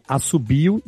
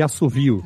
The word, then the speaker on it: assobio: E por isso que tem palavras que tem as duas pronúncias, né? Que é assobio e assovio. – Ah. – Subaco e suvaco.